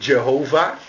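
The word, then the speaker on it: Jahweh: J-H-W-H. Wij zeggen dan Javé, zeggen wij. En de Engelsen die zeggen altijd Jehovah. Maar wij zeggen Javé. Althans, zo zeg ik het. Ja, ik moet een beetje afwachten hoe die naam wordt uitgesproken. Het schijnt niet helemaal bekend te zijn. Maar goed, ik hou het maar bij Javé.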